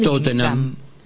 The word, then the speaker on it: Tottenham: En el caso de Tottenham y Twickenham como terminan en enam es clavado que no se pronuncia, no se aspira la H.